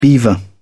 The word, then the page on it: beaver: British English